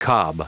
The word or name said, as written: cob